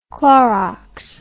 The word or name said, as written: Clorox